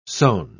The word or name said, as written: sone